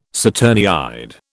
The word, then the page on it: saturniid: British English